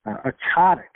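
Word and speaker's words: ptotic: Ototic, so that's a silent T, P-T-O-T-I-C. Ototic tip would just be a droopy tip, kind of like Jimmy Durante.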